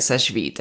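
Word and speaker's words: Sechevite: You know, she's like, you just say it Sesh Vite.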